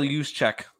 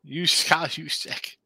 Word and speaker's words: Juszczyk: Juzic, whatever. – Kyle Juszczyk. – Juszczyk.